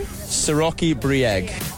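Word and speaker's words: Široki Brijeg: Siroki Brijeg.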